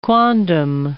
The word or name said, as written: quondam